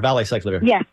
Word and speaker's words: valacyclovir: The Valtrex that they put me on, Valciclovir, I don't know if I'm pronouncing it correctly. – Acyclovir, Valacyclovir. – Yes, that's it.